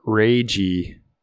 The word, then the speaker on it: Leiji: Leiji Matsumoto passed away. He was 85. So pour one out for, it's Leiji or Reiji. In Japanese, they don't have a difference between the L and the R.